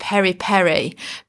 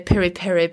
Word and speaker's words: Piri Piri: So I wanted to share with you today how to do a simple Nando's Piri Piri chicken. Or do you pronounce it Peri Peri? Because I think it depends on whether or not you're more Portuguese or South African when you're talking about it.